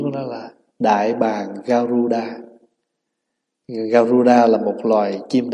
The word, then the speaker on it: Garuda: Tiếng Phạn đọc là Garuda, mà mình có nhiều cách phát âm. Một là mình đọc là Kalola, có khi mình âm là Yết Lộ Trà.